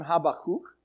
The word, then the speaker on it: Habakkuk: I know that in some circles his name is pronounced Habakkuk, but that is uh, that that's that's in the same category as Agia. Okay, all right. So Habakkuk is his name, Habakkuk, uh, and uh, he writes this and this is fascinating.